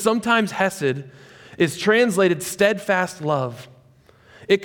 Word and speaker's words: chesed: It's the word chesed. If you want to say it right, I think you have to do a little phlegm in the beginning. Chesed.